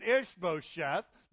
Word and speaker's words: Ishbosheth: In the Hebrew, we would pronounce it Ishbosheth.